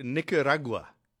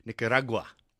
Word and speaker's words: Nicaragua: All right, just before we go to our interview, Nicaragua is how you pronounce it. You put the emphasis on the ra in the middle.